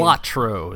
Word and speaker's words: Balatro: Balatro is what I'm choosing to go with. – The Balatro? – No, it's a B with a B. Balatro. – Balatro. – Balatro. – Okay. Hey, you can, Balatro. – The creator doesn't really know what it's pronounced. He says the most famous one will win.